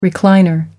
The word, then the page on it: recliner: American English